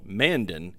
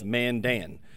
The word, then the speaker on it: Mandan: You know, I need to make a note here because I under the assumption it was called Mandan, but it's not. It is Mandan. You have to pronounce it that way when you're up here for sure.